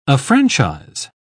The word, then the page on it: affranchise: British English